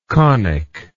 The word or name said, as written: conicalness